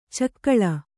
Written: ಚಕ್ಕಳೆ

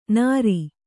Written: ನಾರಿ

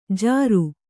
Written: ಜಾರು

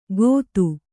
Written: ಗೊತ್ತು

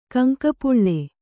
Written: ಕಂಕಪುಳ್ಳೆ

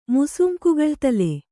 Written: ಮುಸುಂಕುಗಳ್ತಲೆ

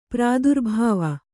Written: ಪ್ರಾದುರ್ಭಾವ

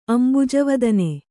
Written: ಅಂಬುಜವದನೆ

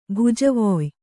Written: ಭುಜವೊಯ್

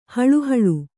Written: ಹಳಹಳಿ

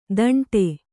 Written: ದಂಡೆ

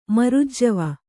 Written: ಮರುಜ್ಜವ

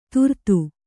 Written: ತುರ್ತು

